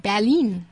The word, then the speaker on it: Berlin: That's how they say it, Berlin.